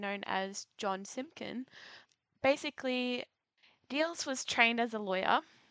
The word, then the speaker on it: Diels: One individual in particular that I thought was worth mentioning is, I also apologize, I may pronounce this wrong, is Rudolf Diels or Diels. – No, you had it right.